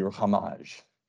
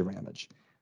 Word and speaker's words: Ramage: Now, my name is French and we butcher it, so I might as well butcher his and just say Father Georges Lemaître. So mine's supposed to be Ramage, but we say Ramage.